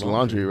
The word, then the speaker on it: Laundrie: Is it laundry? How do you say that? – That's pretty like much laundry, right? – Laundry.